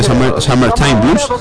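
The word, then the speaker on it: Summertime Blues: Decir que los Stray Cats hicieron una muy gran versión de Summer Time Blues, sería la manera correcta de pronunciarlo, que también merece la pena escuchar.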